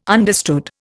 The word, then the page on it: understood: Indian